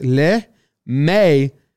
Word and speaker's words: LeMahieu: Baseball Reference has his pronunciation as LeMahieu. Hugh. It's H-Y-O-O. Hugh. Hugh. That's a weird way. H-Y-O-O.